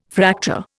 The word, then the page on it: Fraktur: Indian